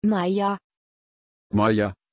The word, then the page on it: Maija: German